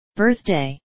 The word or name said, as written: birthday